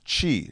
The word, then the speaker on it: chi: That's the number 3 and the word chi. It's spelled C-H-I, but it's pronounced C-H-E-E.